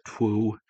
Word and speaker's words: twue: And if you're new, it sounds like I just said true, T-R-U-E. But in actuality, this is spelled T-W-U-E. Some people will pronounce it twoo, or, you know, kind of like the best Bugs Bunny voice, if you will.